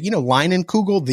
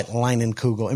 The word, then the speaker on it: Leinenkugel: So nice try, Leinenkugel. Am I saying that right? Leinenkugel? – Yeah, okay.